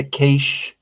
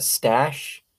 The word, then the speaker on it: cache: In this sort of ultimate battle to find the cache, right? I always used to pronounce that stash. I feel like I'm gonna just keep calling it a stash.